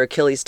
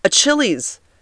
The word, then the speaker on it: Achilles: So it's spelled A-C-H-I-L-L-E-S. I know that this is called your Achilles tendon. I have heard two people in the last month pronounce this Achilles. Achilles. And these were grown people. Like, how do you go through your life and not know that that's called the Achilles tendon, not Achilles?